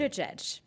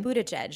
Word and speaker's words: Buttigieg: Buttigieg. – Say it again. – Buttigieg.